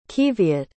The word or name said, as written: qiviut